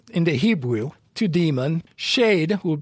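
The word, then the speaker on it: shade (שד): The Hebrew word will be shade. Phonetically, S-H-E-D.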